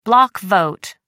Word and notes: block vote: American English

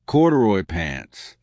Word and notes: corduroy pants: American English

